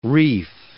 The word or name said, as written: reef